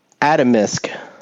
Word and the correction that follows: Atomsk: It's spelled Atom. Atomsk.